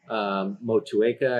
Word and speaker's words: Motueka: So we combined it probably about two parts to one with Moteca. Motueka, I guess, if you want to pronounce it correctly.